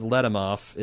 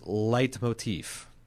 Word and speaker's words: leitmotif: tells me that the correct pronunciation of Letimoff is leitmotif.